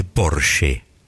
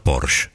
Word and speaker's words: Porsche: La marca es alemana y se dice Porsche. No Porsche. – Porsche. – Esa E final en alemán se pronuncia.